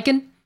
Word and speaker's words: Steichen: Steichen.